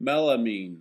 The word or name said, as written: Melamine